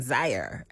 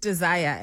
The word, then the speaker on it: Dzire: But the way it's spelled D-Z-I-R-E. So in your head, you're going Zaire. – And then, Zire. – Yeah. And then you're just like, no, it's actually, Zire. It's pronounced Desire.